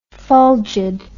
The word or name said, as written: fulgid